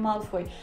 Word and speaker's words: Малфой: Ну, для меня резануло слух просто потому, что я привыкла к Малфой как в английском, так и в русском. И я когда читаю, я тоже всегда читаю с ударением Малфой.